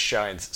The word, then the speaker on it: shins: Okay, so the currency in the Ninth World is called S-H-I-N-S. Most people pronounce it shins. When I started reading it, I started pronouncing it shines. So I may say either word, don't fault me for that.